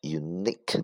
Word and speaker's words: unique: unique，它的发音重音在后边，u。